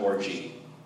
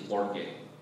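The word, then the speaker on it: orge (ὀργή): It's not orgy. I think it's orge. O-R-G-E. Okay, don't say, it's orgy. No, I did not teach you that.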